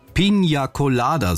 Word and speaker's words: Piña Colada: Man hätte es vielleicht der Band vorher sagen sollen, dass das Getränk eigentlich korrekt heißt Piña Colada, so wird's ausgesprochen, aber als der Song erschienen ist, da ist das möglicherweise so vielen gar nicht aufgefallen.